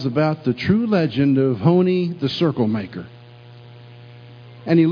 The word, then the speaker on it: Honi: And the only one that was left of that type was an old sage by the name of Honi, spelled H-O-N-I, pronounced Honi.